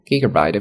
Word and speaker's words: gigabyte: include thirty two gigabyte of storage，三十二 G 的一个内存。 The mid-tier model，那么再往上中档的手机的内存呢，has one hundred twenty eight gigabyte。一百二十八 G，and the top tier packs two hundred and fifty six gigabyte of storage。